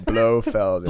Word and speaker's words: Blofeld: What action piece I really liked is when Blofeld, is my saying it right? – Blofeld.